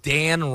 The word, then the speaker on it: Dan: Dan. – Hey. Ah. Three-letter name? Is that what it is? – Well, not only is it a three-letter name, but Dan rhymes with man.